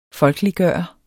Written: folkeliggjorde

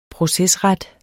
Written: procesret

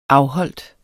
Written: afholdt

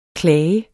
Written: klage

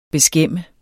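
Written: beskæmme

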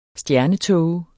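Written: STJERNETÅGER

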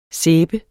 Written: sæbe

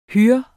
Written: HUR

